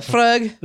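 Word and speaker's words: Laphroaig: So here I'm drinking Lafroy, right? – Is that... Lafroig.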